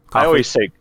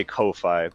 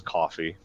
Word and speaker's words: Ko-fi: what is it, coffee? Is that how you pronounce it? – Yeah. – Coffee. I always say Ko-Fi, but I know it's coffee because the logo is a coffee cup.